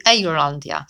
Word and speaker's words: Eirlandia: natomiast on ma swoją nazwę Irlandia, albo raczej to się mówi Eirlandia, czyli tak jak Eiru, okay? Tak jak w starym stylu się to wymawiało i to jest ten teren,